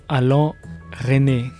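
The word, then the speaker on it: Alain Resnais: Esta semana continuamos con la semana de videoarte y tenemos también, esta semana y las dos siguientes, un homenaje al director Alain René. Lo dije bien en francés, ¿no, Javi? Dice que sí.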